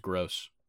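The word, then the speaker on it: Groß: Yeah, and it's actually pronounced gross.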